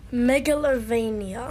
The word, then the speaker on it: Megalovania: Megalovania. – Try that again. So why don't you pronounce every single letter in the particular Megalovania? Try saying it like that. Megalovania. – Megalovania. – That's better.